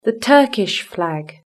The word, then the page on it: the Turkish flag: British English